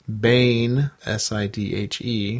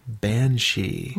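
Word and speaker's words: Bean Sidhe: From your excellent feature on the Cabal, I'd like to point out that Bane, S-I-D-H-E, where I was saying I'm not quite sure how to pronounce this, right. Is pronounced Banshee. – Right. Instead of Bane Sidhe or whatever you were saying.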